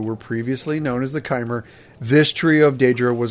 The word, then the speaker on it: Chimer: I had a feeling it was Chimer. Confirmed. That's how you say it.